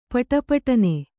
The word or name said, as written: ಪುಟಪುಟನೆ